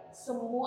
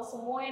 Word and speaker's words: Semu: Semu atau semue nih bacanya gitu. Aku kira itu kayak bahasa-bahasa jadul gitu. – Enggak. – Oh, ternyata emang dari smoothie ya.